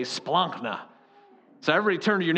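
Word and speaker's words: splankna (σπλάγχνα): S-P-L-A-G-K-N-A is roughly how you'd spell it, splankna. You got to kind of get it out of the throat there.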